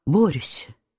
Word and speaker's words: Борис: «Что же вы мне скажете, князь, о моем Борисе?» — сказала она, догоняя его в передней. Она выговаривала имя Борис с особенным ударением на «о».